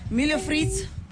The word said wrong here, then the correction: milho frito: No. Am I saying it right? – Close. Yeah, sure. – How do I say it? – Milho frito. Yeah.